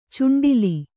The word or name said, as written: ಚುಂಡಿಲಿ